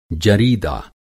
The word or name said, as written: जरीदा